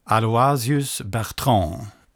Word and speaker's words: Aloysius Bertrand: Den bygger på tre texter ur den franska poeten Aloysius Bertrand.